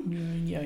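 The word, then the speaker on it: Mjölnir: Genau, weil sie hat den zerbrochenen Mjolnir, hoffe, das richtig ausgesprochen. – Mjolnir, ja.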